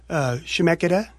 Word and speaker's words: Chemeketa: I know that Chemeketa.